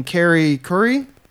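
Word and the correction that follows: Callie Khouri: Callie Curry.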